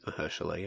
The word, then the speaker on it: Mahershala: Mahershala, is that it? – I think it's Mahershala, yeah. – Mahershala Ali. Obviously it's topical. Is that it? – Yeah, that's it.